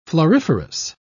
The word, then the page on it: floriferous: British English